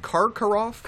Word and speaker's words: Karkaroff: I'm pronouncing it right. Karkaroff.